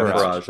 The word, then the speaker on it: Farage: Yeah, Farage, is that it? – I don't know how to pronounce it. Duped into using Republican slogan in birthday video message. – Oh, Nigel Farage. – I think Farage, that's it, yeah.